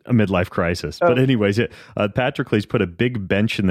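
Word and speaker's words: Patroclus: Patrocles. – Patrocles.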